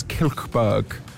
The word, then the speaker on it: Kilchberg: I've been told that the way I pronounce my birth town is Kilchberg.